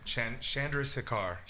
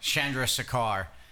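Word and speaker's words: Chandrasekhar: And you were actually in the same bathroom as, and now I'm going to butcher his last name, Jay Karsharaka Rasu. – Chandra Sekar. – Chandra Sekar.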